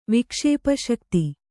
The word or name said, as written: ವಿಕ್ಷೇಪಶಕ್ತಿ